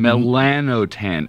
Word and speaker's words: melanotan: It's something called melanotan.